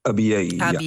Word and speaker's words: Abyei: Abyei, zeg ik het goed? – Abyei.